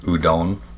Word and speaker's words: Udon: Yeah, well, Yuden, I believe I'm pronouncing it right, Yuden, kind of like the noodle. – Udon? – Yeah.